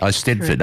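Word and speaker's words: Eisteddfod: I Stedford.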